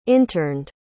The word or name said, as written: interned